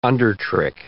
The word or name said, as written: undertrick